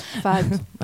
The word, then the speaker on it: fado: Ça m'a donné envie de pleurer, bah, comme le fado. Je le prononce bien ou pas? – Fado.